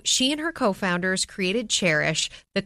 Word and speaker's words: Chairish: And this week's No Limits Entrepreneur is Anna Brockway, who is the co-founder and president of Chairish. And just so you know, it is spelled like a chair, C-H-A-I-R-ish, because it is a curated online marketplace for buying and selling vintage and used furniture.